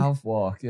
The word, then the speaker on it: Southwark: Southwark, yeah. So there's apparently, again, Northern. Apparently there's a place in London called Southwark, but they spell it Southwark in a bid to confuse Northerners, I assume.